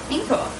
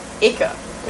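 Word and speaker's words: ichor: Ica. – It's I-C-H-O-R. Ica. – Is there an N in it? – No. – Oh. – It's not like anchor.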